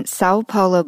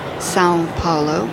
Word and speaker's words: São Paulo: São Paulo, I'm learning to pronounce that O with an accent, at an exhibition that's the 32nd biennial of this city.